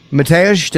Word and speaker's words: Mateusz: Mateusz, did I say it right? – Yeah, right. Good.